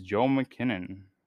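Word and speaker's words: Joel Kinnaman: It is Joel McKinnon. I believe that's how you say his name. Yep, Joel McKinnon. That's how you say it.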